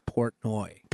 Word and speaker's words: Portnoy: I think it's Portnoy. – Portnoy, yeah. Exactly. Dave Portnoy. What is it? Dave Portnoy? Yeah, that's it. Portnoy. El Presidente. – Fucking Portnoy.